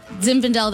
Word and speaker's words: Zinfandel: Zinfandel, that's the light pink one, yeah, I yeah, think.